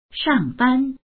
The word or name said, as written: shang4ban1